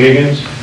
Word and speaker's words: Higgins: Yes, Stephen Kay from the District Attorney's Office in Los Angeles, and my last name is spelled K-A-Y. – Stephen Kay from his mother. – Okay. – Gary Higgins, H-I-G-G-I-N-S, classification of parole representative. – Laura Fiamate, F-L-E-M-A-T-E, office assistant.